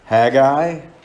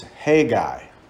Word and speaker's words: Haggai: When we were in Canada, we did a study on this book, a bunch of men and I, and they insisted it's Haggai. And I said, no, that sounds kind of weird, Haggai. And Americans are like, Haggai. And every pronunciation I found is Haggai, Haggai, Haggai.